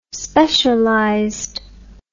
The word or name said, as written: specialized